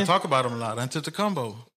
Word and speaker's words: Antetokounmpo: Akatumpo, what he say his name? – Antetokounmpo.